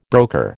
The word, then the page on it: broker: American English